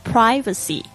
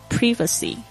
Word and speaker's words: privacy: 这个 privacy 它有两个发音，大家特别注意一下，美式发音呢是念这个 I 呢是发 I 的这个声音 privacy，英式发音呢通常会念 privacy，大家稍微注意一下。